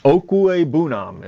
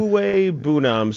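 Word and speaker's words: Okwuegbunam: Okwigbunam is what they said. That's how it is in our pronunciation guide. I'll probably never get that right again. – Okwigbunam.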